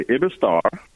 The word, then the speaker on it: Iberostar: It's called the Ibistar. Some people pronounce it differently. It depends on where you are and what your native tongue is. But it's spelled Iberostar.